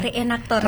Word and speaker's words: Reenaktor: Pernah magang di Museum Blambangan Banyuwangi dan Museum Reenaktor, bacanya apa? Reenaktor ya? – Reenaktor. – Reenaktor Malang.